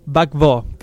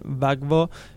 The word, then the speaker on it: Gbagbo: Dígalo, es en francés. – Claro, Gbagbo, creo que sería la pronunciación más aproximada, si creemos.